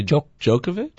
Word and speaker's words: Djokovic: I think it might be Djokovic. – Djokovic. But you almost have to put him in the team picture. – Oh, yeah. – Right now. – Yeah. Him, Djokovic.